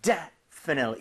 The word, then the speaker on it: definitely: 이렇게 하게 되면 definitely 이렇게 앞쪽에 강세가 가면서 뒤에 틀리라고 할 여유가 안 생기게 돼요. – 네. – 그렇게 돼서 definitely 이런 이상한 발음이 아니라 definitely, definitely 이렇게 발음이 될 수밖에 없습니다.